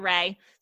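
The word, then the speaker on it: Rea: You can find me on Instagram at Crystal Ray. So my name is spelled super complicated. It is K-H-R-Y-S-T-L-E-R-E-A.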